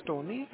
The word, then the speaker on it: Tony: My name is Tony. It's spelled like T-O-N-Y. First initial of my last name is S-S-S-M.